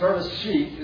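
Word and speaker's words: bereshit (בראשית): The first is, in the Hebrew text, bereshit is accented, that's the word beginning, with a disjunctive accent. So on the last syllable, and disjunctive accents indicate that a word has its own independent accent.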